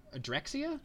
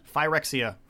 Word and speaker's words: Phyrexia: Jarsil discovers a spell that lets him get into Adrexia? – Phyrexia.